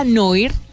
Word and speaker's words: Noir: Una se llama Cami, eh, Cam, se escribe Cámara Noir. N-O-I-R, y es una aplicación muy popular y poderosa para crear hermosas imágenes en blanco y negro. Cámara noir, diría en francés. Pero búsquela Camera cámara noir.